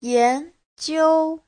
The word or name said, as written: yan2jiu1